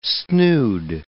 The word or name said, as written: snood